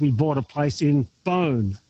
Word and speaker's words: Beaune: Well, Beaune, you said it very well.